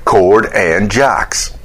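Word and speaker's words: Chord and Jocks: Yeah, pronounce it that way. It rhymes. You know, you got his name, Jordan Cox. Then you do the C and the J, and you kind of reverse them a little bit. Cord and Jocks.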